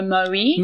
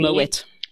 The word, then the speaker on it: Moët: But no, a lot of people think that they must pronounce it the French way and go Moet. – Moet. – But meanwhile, Moet. The guy, the family. – It's actually Moet. – Yeah, are originally from Holland, from the Netherlands.